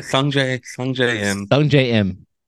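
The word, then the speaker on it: Sungjae Im: I don't even know how to say it. Sung Im? Sung Young Im? – Sung Jae Im.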